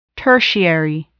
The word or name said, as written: tertiary